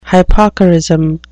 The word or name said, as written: hypocorism